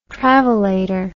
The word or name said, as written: travelator